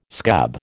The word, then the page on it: scab: American English